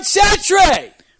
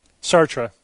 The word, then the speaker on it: Sartre: Jean-Paul Charles Aimard Sartre. – Whoa. – Sartre.